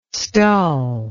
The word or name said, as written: stull